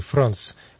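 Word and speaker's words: franc: А по-французски даже, на современном французском языке, фран читается, пишется франк, означает честный.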